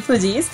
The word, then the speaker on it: Fugees: Efectivamente, la banda sí se llama así, The Fugees. Realmente se pronuncia así, Fugees.